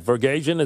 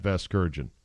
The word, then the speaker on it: Vasgersian: Vergasian, is that how you say it? – No, you're not saying it right.